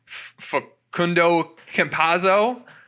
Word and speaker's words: Facundo Campazzo: Facundo Campazzo.